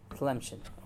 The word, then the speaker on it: Tlemcen: I don't know how to say this word. Tlemcen? Huh? – Tlemcen.